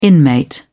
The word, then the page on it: inmate: British English